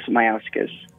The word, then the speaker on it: Majauskas: Joining us on the phone is Jonas Mizaus. I'm not going to say his name correctly. Jonas, say your last name for us. Hello? – Hi, Jennifer. Jonas Mayauskas.